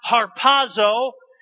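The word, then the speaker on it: harpazo (ἁρπάζω): And they translate from the Greek word, and the Greek word is pronounced harpazo, and it's used 13 times in your King James Bible.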